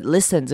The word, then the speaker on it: listen: 这边的 listen 要注意的是，它第一个 i 要念短母音 i 的声音。记得这个字是很常听到别人念错的，要念成 i，不要念成 e 的声音。所以这个 listen 这个字要念成 listen， li i i i 的声音，短母音 i， listen， listen carefully。